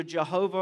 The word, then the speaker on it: Jehovah: It is the covenant name for God, pronounced either Jehovah or Yahweh, as best we can tell.